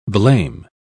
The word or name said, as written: blame